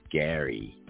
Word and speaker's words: Jerry: And it was just awesome, even though his name is spelled Jerry, it's pronounced Gary.